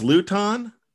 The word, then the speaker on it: Luton: The quarterback, man, Jake, is it Luton or Luton? I mean, I don't even know how you pronounce his last name, to be quite honest with you. – I believe it's Luton.